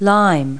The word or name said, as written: lime